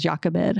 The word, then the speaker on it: Jochebed: I think you say her name is Jochebed. I think is how you say it.